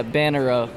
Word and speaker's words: Bannerot: Yeah, Bannerow. – Bannerow. Okay. – Yeah, the T is silent.